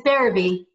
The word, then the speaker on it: Berube: It's Berube, yes.